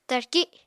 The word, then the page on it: tarki: Apatani